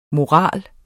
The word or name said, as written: morale